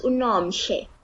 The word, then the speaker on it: Nomhle: So So my full name is Nomhle.